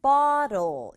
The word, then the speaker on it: bottle: Bottle, TT가 있잖아요. 이 bottle이라고 해도 되지만 중간에 있는 T는 소리가 약해진단 말이에요. 그래서 bottle보다는 bottle 이렇게 약하게 발음하면 훨씬 자연스러워요. bottle은 병, 그리고 paper, paper, paper 세 박자 안 하도록 조심해요.